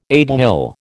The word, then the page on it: aiguille: American English